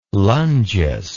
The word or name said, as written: lungeous